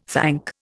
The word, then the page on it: thank: Indian